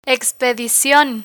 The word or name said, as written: expedicion